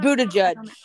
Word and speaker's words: Buttigieg: I had somebody ask me about making a and I'm gonna mispronounce his name, I feel like, but Budacek hat. Am I pronouncing that? Buttigieg. Okay.